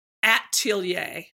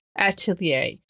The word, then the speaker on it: Atelier: How do you say that? Adelaire? – Atelier. – Atelier.